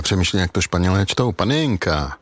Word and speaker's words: Panenka: Opravdu Panenka, jak to vyslovuju, a je to podle našeho Antonína Panenky, protože ten tak okouzel Španělsko, že vlastně po něm nazvali fotbalový časopis.